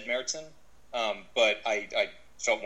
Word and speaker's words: Märzen: Well, a Märzen, the German pronunciation Märzen, the A has an umlaut over it, is an October, September style of beer.